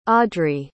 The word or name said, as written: audry